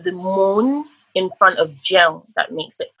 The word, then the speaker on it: Jeun: Jeun. – Jeun. So how do you spell that? – J-E-U-N. – J-E-U-N.